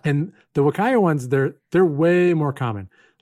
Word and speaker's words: Huacaya: I'll just tell you, it's Huacaya. I always called it like Huacaya while I was working on the project. Nobody corrected me. I only recently went and looked up how do you pronounce this word. – And it's Wakaya? – Yeah. I like to think of it as a small child trying to say Wakanda forever.